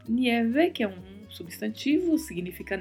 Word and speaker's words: nieve: e particularidade de pronúncia aí é a letra E, que também é fechada, né? Nieve, e a letra V com leve som de B. Nieve, nieve. E muito cuidado com o I de nieve, ele tem que aparecer na pronúncia. Então temos que falar nieve.